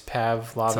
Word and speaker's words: Pavlovitz: And I'm not entirely sure how to pronounce his name, John Pavlovitz, Pavlovitz. – Sounds right.